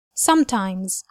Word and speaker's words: sometimes: sometimes وبننطقها كده وبنركز على أول جزء اللي هو some